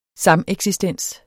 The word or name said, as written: sameksistens